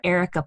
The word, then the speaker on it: Areca: Erica palm, which is spelled A-R-E-C-A, Erica palm, and the money plant.